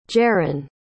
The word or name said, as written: jarron